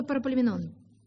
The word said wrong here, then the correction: Паралипоменон: Я неправильно делаю ударение? А я что сказала? Параполеменон. Ай, извините, Паралипоменон.